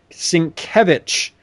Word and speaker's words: Sienkiewicz: His name is pronounced Sienkiewicz. Bill Sienkiewicz was there. And I knew that. – Well, I didn't know that. I've always mispronounced it. I'd always pronounced it Sienkiewicz, and he says Sienkiewicz.